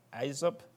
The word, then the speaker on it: Aesop: You know the famous poems of the Indian poet, how do you pronounce his name, Aesop? That's the way, Aesop, okay.